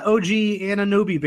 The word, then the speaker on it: OG Anunoby: And I'm on the OG Anunoby bandwagon. I know that's not how you say his name.